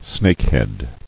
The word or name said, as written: Snakehead